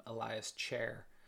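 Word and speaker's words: Ilias Chair: Well, I mean, not only because of the name, Elias Chair, but... And I'm sure that's exactly how it's pronounced. – It is.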